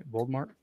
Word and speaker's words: Voldemort: Voldemort? – Yeah.